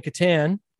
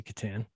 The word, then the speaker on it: Catan: So between kind of when you start playing Catan, I'm in Canada, so I say Catan. I know some people say Catan, Catan, yeah. Tomato, tomato.